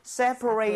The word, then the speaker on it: separate: separate처럼 쓰셔야 돼요. so 아. – rate이 아니라 rut.